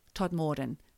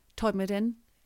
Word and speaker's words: Todmorden: So now we go to a very famous place for ufologists, Todmorden. Todmorden. Todmorden. That's how they say it in West Yorkshire, is known as UFO Alley.